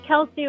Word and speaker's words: Kelsey: Oh, it's Kelsey with a K.